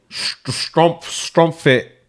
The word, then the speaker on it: Schtroumpfette: Original French name. Strumpfette. It's spelled S-C-H-T-R-O-U-M-P-F-E-T-T-E. That's Strumpfette.